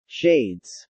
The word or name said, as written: shades